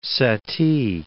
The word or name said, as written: Satie